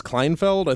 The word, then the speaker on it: Kleinfeld: Basically, this week, sudden ouster of its CEO, Klaus Kleinfeld, I think I'm saying that correctly. – Yeah, yeah, no, that's right.